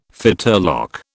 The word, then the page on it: fetterlock: British English